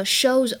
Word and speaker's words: show's: 我们今天的话呢，学的这句话其实非常的简单，嗯，叫做 shows over。 shows over shows over，大家要注意这个一撇 s 呢，读的时候不要读成 s，要读成 z。